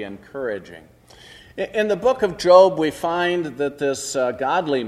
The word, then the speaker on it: Job: And yes, I know some of you still want to pronounce it Job. It's Job.